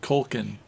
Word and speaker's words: Culkin: It seemed like he had an excellent life with his son Macaulay Culkin, and he had a good life. Am I saying that right? – Culkin. But No, you're saying it right.